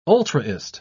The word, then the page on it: ultraist: British English